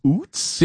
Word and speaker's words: Utz: And the 630 here says, it's not Utz, it's Utz. Do you have a confirmation on pronunciation? I always thought it was Utz. It's U-T-Z. – Utz. – Utz? – This is one of those rare occasions where you and I agree on pronunciation. It's Utz.